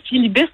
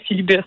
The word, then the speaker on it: Filibuste: Parce que là, ben, oui, moi, moi je le dirais filibus, mais là, j'avoue, c'est complètement inventé. Donc, t'es la première à me proposer peut-être euh, une autre prononciation, là, mais moi, je dirais filibus.